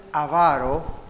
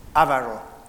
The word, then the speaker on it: avaro: Ahora, cuando uno es avaro, escucha cómo lo pronuncié, especialmente para los que están en radio. Tenga cuidado con esa palabra. Cuando uno tiene este problema de la avaricia y uno es avaro, no avaro.